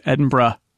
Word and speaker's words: Edinburgh: It's Glasgow. – Glasgow. Not Glasgow. – Not Glasgow. Glasgow. No. It's like, it isn't Edinburgh either. It's Edinburgh.